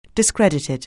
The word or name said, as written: discredited